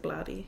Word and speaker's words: Blatty: Blatty. William Peter Blatty. That's how you pronounce that, right? – In my brain, I pronounce it Blatty. – Yeah, Blatty. William Peter Blatty.